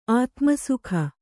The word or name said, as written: ಆತುಮಸುಖಿ